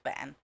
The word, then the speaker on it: bean: And for you, this is where you thought said beans, because B-E-A-N is pronounced ban.